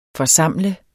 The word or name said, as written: forsamle